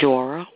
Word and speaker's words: Jora: No, ma'am. It's Jora with a J.